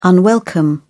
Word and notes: unwelcome: British English

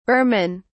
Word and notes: burman: British English

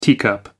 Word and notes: teacup: American English

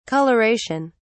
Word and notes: colouration: British English

